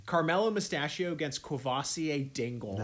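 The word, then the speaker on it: Courvoisier: Dad's saying it's Quavassier. – Yeah, that's what it is. – Shit. – Corvassier? – Quavassier. – It is Quavassier.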